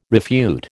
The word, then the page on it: refute: American English